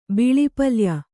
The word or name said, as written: ಬಿಳಿಪಲ್ಯ